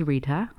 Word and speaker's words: Rita: My name is Rita, R-I-T-A, and Rochelle is spelled R-O-C-H-E-L-L-E.